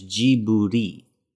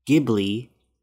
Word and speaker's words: Ghibli: And then when you translate it into Japanese, you pronounce it with the characters Jiburi. So you can say Jiburi, Ghibli, because that's what he actually named it after. Or you can say Ghibli because it's kind of like the in-between.